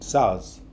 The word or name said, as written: sayaz